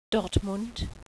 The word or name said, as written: Dortmund